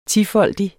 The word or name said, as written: tifoldig